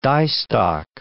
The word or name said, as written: diestock